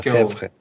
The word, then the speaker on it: Lefebvre: Lefebvre, como é que tu, tu pronuncia isso aí? Tu é Lefebvre. – É Lefebvre. Lefebvre.